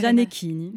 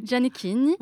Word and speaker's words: Giannettini: Alors là, il faut m'appeler, parce que pour l'italien, le corps, c'est Giannettini. – Giannettini. – Bravo.